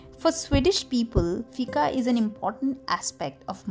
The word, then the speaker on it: fika: Okay, guys, the next word is fika. It's F-I-K-A, and you know, how can you pronounce it? It's fika.